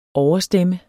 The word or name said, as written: overstemme